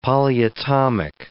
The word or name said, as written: polyatomic